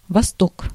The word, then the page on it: восток: [vasˈtok]; vostok